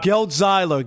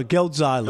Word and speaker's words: Geltzeiler: Geltzeiler. Geltzeiler.